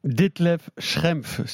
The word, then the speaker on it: Detlef Schrempf: Detlef Schrempf, Seattle Supersonics.